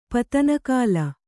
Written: ಪತನಕಾಲ

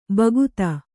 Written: ಭಕ್ತ

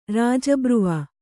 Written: ರಾಜಬ್ರುವ

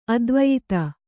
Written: ಆಧಿವ್ಯಾಧಿ

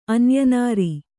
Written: ಅನ್ಯನಾರಿ

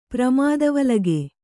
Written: ಪ್ರಮಾದವಲಗೆ

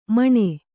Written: ಮನ

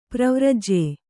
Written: ಪ್ರವ್ರಾಜ್ಯ

